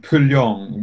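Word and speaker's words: Pù Luông: And that's in the areas called Pulyong, which is spelled Pulluong. Is that right? – That's right. Yeah.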